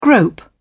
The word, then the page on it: grope: British English